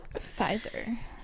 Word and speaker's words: Pfizer: Because there's a silent P. – Pfizer.